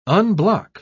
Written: unblock

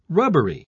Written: rubbery

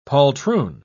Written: poltroon

